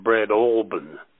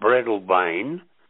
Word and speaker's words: Breadalbane: It's in Tasmania, and the name of the place is Breadalbane, but I called it Breadalbane because I had a habit of pronouncing things phonetically. So if I looked at it and it looked like it should be pronounced that way, that's the way I would pronounce it, until the manager would say, listen, cock, this is Tasmania, that is Breadalbane, not Breadalbane. Another one was Sorrel. I called it S-O-R-E-L-L, with sorrel, and I had a lot like that.